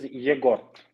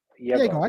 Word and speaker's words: Egor: My name is Egor. – Oh, Egor. – Egor. It's not Igor. Yes, that's completely different name of the completely different roots.